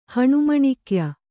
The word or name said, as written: ಹಣುಮಣಿಕ್ಯ